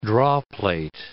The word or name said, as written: drawplate